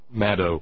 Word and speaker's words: Maddow: Come on, Maddow rhymes with shadow.